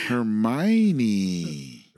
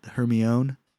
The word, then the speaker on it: Hermione: And then eventually the movies came out, and I'm like, "That's how you pronounce their name?" – Oh shit! – Right. – I'm like, what the fuck? I'm like, oh god, I'm the worst. – Hermione. – Right? Hermione.